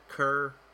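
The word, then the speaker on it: Kir: It kind of sounds like that, ker. The way kier is spelled, it's K-I-R.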